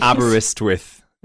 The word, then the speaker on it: Aberystwyth: Can you pronounce it for me again, in Aberystwyth in Wales. – Aberystwyth, okay.